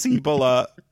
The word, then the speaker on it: Cibola: I think it's pronounced Cibole. – it's Cibola.